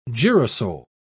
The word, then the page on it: girasol: British English